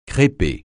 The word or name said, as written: crêper